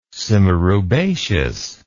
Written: simaroubaceous

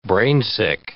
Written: brainsick

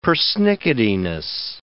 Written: persnicketiness

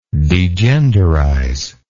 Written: degenderize

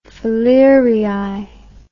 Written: falerii

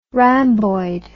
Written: ramboid

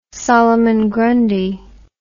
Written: solomon grundy

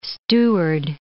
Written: steward

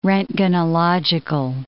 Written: roentgenological

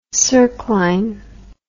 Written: circline

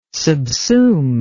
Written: subsume

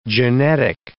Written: genetic

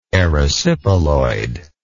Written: erysipeloid

